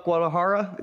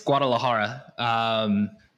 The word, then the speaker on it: Guadalajara: So tell me about Guadalajara. Did I say that correctly? What did you decide on that? – Yeah. So you almost said it correctly. It's Guadalajara.